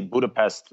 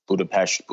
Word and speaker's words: Budapest: And then the sound, like if it was Budapest, it would be spelled B-U-D-A-B-E-S-Z-T. Their sound or their soft S is an S-Z. But honestly, Budapest, no one will really bat an eye. I mean, sometimes you get some people, Budapest, Budapest, but really Budapest or Budapest, either one is fine.